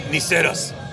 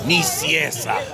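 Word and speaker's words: Nicieza: You need me to, Niceros. – Yeah, there you go, Niceros. Right? – Niciesa.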